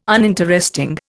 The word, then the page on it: uninteresting: Indian